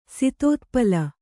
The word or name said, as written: ಸಿತೋತ್ಪಲ